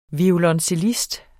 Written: violoncellist

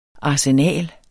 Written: arsenal

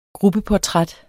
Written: gruppeportræt

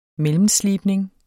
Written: mellemslibning

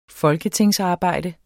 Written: folketingsarbejde

